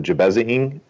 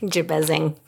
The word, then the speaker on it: Jabezing: And didn't you and Sean did an episode recently where it was the Jabezing episode? Did I say that right? – Jabezing. Yeah.